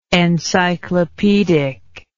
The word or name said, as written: encyclopaedically